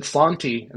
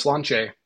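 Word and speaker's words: Slàinte: And then at the end, he said Slanti instead of Slanche.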